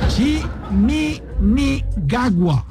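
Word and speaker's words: Chiminigagua: Él hace parte y es el director, uno de los directores de la Fundación Chimini Nagua. No, ya lo dije mal otra vez, pero voy a ensayarlo aquí, Chimini. Chimini Gagua. Ahora sí lo dije bien.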